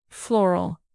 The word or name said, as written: floral